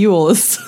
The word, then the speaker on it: Elwes: I saw an AM that Cary Elwes did, and he said it just pronounce it like Elvis but with a W. – Well, that's easy to remember. – Yeah. – So anyways, Cary Elwes.